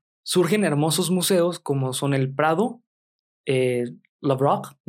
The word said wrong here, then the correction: Louvre: no recuerdo cómo se pronuncia. Louvre. – No, pues se pronuncia diferente. nuestros amigos que saben francés.